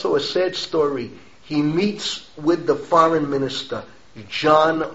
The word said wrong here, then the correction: Jan: Masaryk, if I'm pronouncing John right, J-A-N. Correct me if I'm wrong. How do you pronounce it? – Jan. – Jan. – All the J's are Y's. Jan Masaryk.